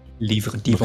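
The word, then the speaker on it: Livre Divin: What did it look like the Libra Divon? – No, it did not. – Livre Divon. Look like that. Livre Divon. The Livre Divon. – Yeah, we have to pronounce it correctly.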